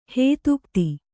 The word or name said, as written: ಹದಗುದಿ